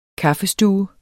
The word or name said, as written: kaffestue